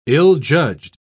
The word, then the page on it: ill-judged: British English